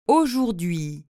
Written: aujourdhui

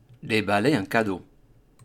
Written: deballer

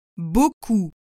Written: beaucoup

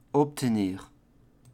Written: obtenir

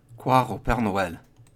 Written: Croire au Pere Noel

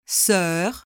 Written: soeur

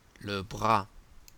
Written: le bras

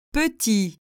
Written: petit

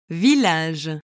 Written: village

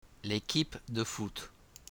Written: l'equipe de foot

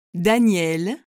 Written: daniel

